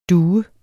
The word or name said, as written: Columbidae